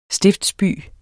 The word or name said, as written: stiftsby